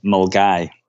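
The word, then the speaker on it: Milngavie: He recently set the fastest known time on the West Highland Way, which is a 96-mile off-road route connecting, I'm not going to say it right, Milgeneve, Milgeneve and Fort William. What is it? Correct me. – We've definitely Scottished you here. It's actually pronounced Mulgai. – Mulgai. It looks nothing like that.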